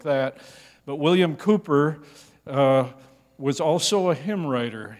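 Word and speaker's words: Cowper: That's C-O-W-P-E-R, pronounced Cooper, William Cooper.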